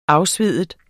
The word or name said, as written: afsvide